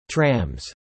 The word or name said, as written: Trams